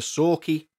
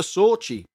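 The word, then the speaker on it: Sauchie: Sorry, the events of the Sorky Poltergeist. Not the Sorchy Poltergeist, which is how I thought it was pronounced until I researched it. And I had to, like, Google how you pronounce it.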